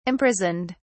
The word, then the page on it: imprisoned: British English